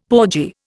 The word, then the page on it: porgy: Indian